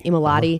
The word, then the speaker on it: Amoletti: Just the emphasis, though, going back to the way he reported that story, David Amoletti, is that how you say his name? – Amoletti.